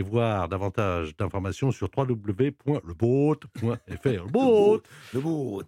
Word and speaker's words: Le Boat: Le, faut le prononcer en français, boat en anglais, le boat. je le dis bien, le boat.